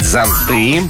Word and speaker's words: зонты: Зонты, все-таки это неправильно, надо говорить зонты.